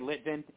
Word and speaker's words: Litvin: Yeah, you can say Litvin, L-I-T-V-I-N.